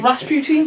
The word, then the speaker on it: Rasputin: Rasputin. That's how they say it. – You just said it, Rasputin? – Yeah, but it's Rasputin.